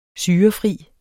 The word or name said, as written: syrefri